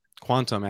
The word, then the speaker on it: Qtum: Quantum actually is how it's pronounced.